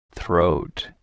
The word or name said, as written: throat